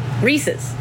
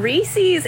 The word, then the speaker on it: Reese's: I've heard it, people there and here pronounce it Reese's. Why are we saying Reese's? It's Reese's. It's Reese's. – Also, if you go to Hershey's Chocolate World, they're not going around being like, yeah, Reese's Peanut Butter Cup.